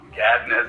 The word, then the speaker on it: Gadden: Gadden, as the way it would be pronounced correctly.